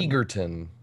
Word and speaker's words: Egerton: Okay, so there's Taron Egerton, Taron Egerton. – Egerton. – He's eager to have his name pronounced correctly.